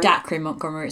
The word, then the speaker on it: Dacre Montgomery: Dacre Montgomery is what I say.